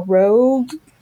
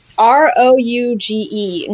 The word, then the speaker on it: Rouge: Uh, okay, so the first one is I don't know how to pronounce it, but it looks like Rouge or Rogue. R O U G E National Urban Park. Um, Rouge. – Rouge. Okay, I don't.